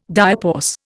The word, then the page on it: diapause: Indian